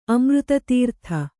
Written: ಅಮೃತತೀರ್ಥ

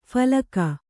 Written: ಫಲ್ಗು

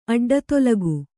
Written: ಅಡ್ಡತೊಲಗು

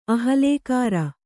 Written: ಅಹಲ್ಲೆಕಾರ

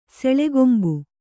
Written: ಸೆಳೆಗೊಂಬು

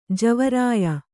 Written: ಜವರಾಯ

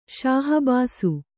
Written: ಶಾಹಬಾಸು

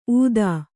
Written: ಊದಾ

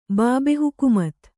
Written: ಬಾಬೆಹುಕುಮತ್

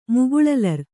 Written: ಮುಗುಳಲರ್

